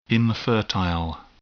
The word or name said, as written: infertile